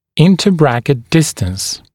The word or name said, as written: interbracket distance